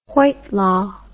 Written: whitelaw

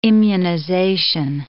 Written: immunization

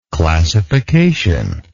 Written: classificational

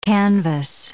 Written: canvas

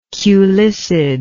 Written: culicid

